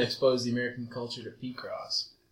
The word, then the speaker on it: Picross: P-Cross 3D 2. – P-Cross? – P-Cross. – How do you spell that? – P-I-C-R-O-S-S.